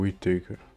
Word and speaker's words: Whittaker: Whittaker, não sei qual é a pronúncia. – Ah, o Whittaker. É o Whittaker.